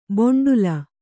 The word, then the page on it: ಬೊಂಡುಲ: boṇḍula